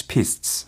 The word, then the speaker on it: pistes: pistes. – 저지대인 안젤에는 몇몇 스키 활강 코스를 닫아야만 했습니다. 라고 했는데, 여기에서 또 PIST라고 발음이 된 P-I-S-T-E-S 라는 것이 활강 코스에 해당하는 말이에요.